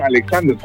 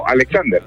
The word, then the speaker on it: Alexander: Braham Alexander. Se escribe raro. Se escribe B-D-A-J-A-N. – Brahan, así se escribe, ok. – Alejandro León Argueta. – Alejandro León Argueta. – No, Alexander.